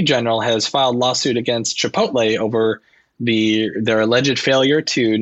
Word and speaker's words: Chipotle: I think it's pronounced Chipotle. I don't know what this Chipotle is, first of all. – Oh, Chipotle? – Chipotle.